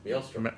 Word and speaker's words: maelstrom: Is that how you say it? – Maelstrom. – Maelstrom.